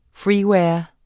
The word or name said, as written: freeware